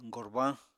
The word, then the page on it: gorbañ: Apatani